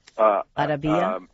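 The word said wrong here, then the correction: Ajdabiya: I think it was Ajdabia, but yes.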